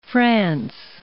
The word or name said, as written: france